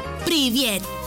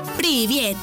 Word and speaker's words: привет: En ruso, hola se dice привет. Привет. Y se escribe P, R, I, V, I, E, T.